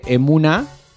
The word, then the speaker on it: Emuna: Estamos hablando con emprendedora de Colonia Valdense, fundadora de Emuna, ¿así se pronuncia? Bien. – Emuna. – Sí, Emuna.